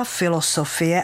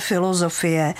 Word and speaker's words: filozofie: A protože ani velký filosof neříká filosofie, ale filozofie, tak tam ta základní podoba je Z.